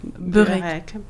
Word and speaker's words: byrek: Alors, en Albanie, ça s'écrit B-Y-R-E-K, mais ça se prononce burek. – Parce que le Y chez nous se prononce U.